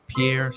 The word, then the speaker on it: Pierre: Pierre, South Dakota. – Pierre, South Dakota? – Okay.